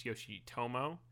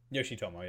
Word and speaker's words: Yoshitomo: I'd like to correct, I mispronounced his name. I said Yoshio, it's Yoshitomo. – Yoshitomo, yep.